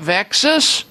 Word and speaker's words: VEXAS: V-E-X-A-S, VEXAS, rhymes with Texas, syndrome.